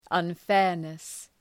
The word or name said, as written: unfairness